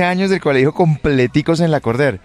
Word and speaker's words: Lacordaire: Es que es un nombre francés. Se escribe Lacordaire, pero se pronuncia Lacordaire.